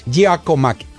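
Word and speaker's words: Giakoumakis: Giacomakis. Como Gio. Como Gia. Entre A y la O.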